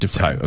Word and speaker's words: Dufresne: It's pronounced Dufresne.